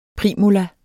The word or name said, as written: primula